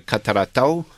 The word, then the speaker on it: Catarratto: Yeah, this is the uh, Catarratto is how they pronounce it, and it's a, a 2017 Nostru uh, Catarratto Lucido 2017.